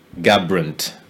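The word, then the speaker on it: Garbrandt: Cody Gabrant.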